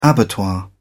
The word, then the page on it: abattoir: British English